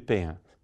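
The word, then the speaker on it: Payens: E se for escrito assim, a pronúncia, né, no alfabeto fonético seria Hugo de Payan. Assim. Payan.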